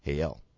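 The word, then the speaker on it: Heyel: It's H-E-Y-E-L. – Okay. – And it's pronounced Hael.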